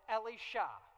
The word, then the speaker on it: Elisha: We began with a guy named Elijah, and then we moved to his predecessor, and a guy named, we read it and we say Elisha, but we go with the Hebrew pronunciation, Elisha, so that we can, and it helps us really keep those, because if you say Elijah, Elisha, which one?